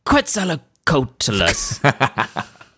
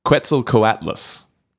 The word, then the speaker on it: Quetzalcoatlus: The Quetzalcoatlus. – Close. It's actually, it's not too bad. It's the Quetzalcoatlus.